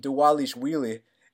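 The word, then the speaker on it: Dvalishvili: We got Merab Dzhuwalishvili. The V's are actually pronounced as W's.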